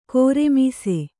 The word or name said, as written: ಘೂರ್ಮಿಸು